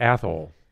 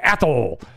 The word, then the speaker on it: Athol: On the way to Athol, which was my favorite city in Massachusetts. – What is it called? – A-T-H-O-L. And they pronounce it like asshole.